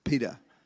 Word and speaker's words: Peter: My name's Peter. Not Peter. That if you're English, Peter, it's like pita bread. Peter. Okay, that's how you say my name.